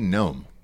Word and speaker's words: Noem: Christy Gnome, like like the little.